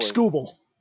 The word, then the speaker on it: Skubal: Round 21, Skubal. Do I pronounce his name correctly? Skubal? – Tarek Skubal.